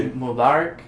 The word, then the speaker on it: Eid Mubarak: Eid Mubarak. – Uh-huh. – And Eid is spelled E-I-D.